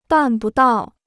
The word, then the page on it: 办不到: bàn bu dào